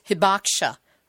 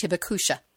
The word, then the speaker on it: Hibakusha: This is the call of the survivors, known as Hibakusha, or sometimes pronounced Hibakusha.